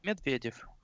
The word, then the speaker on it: Miedwiediew: bo chcę do ciebie powiedzieć Miedwiediew. Jak to w ogóle mogę powiedzieć? Jak to się prawidłowo wypowiada? – Miedwiediew. – Miedwie, Miedwiediew, okej.